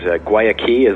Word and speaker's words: Guayaki: The name of the company is Guayaki, as I've learned to pronounce it correctly today, thanks to David.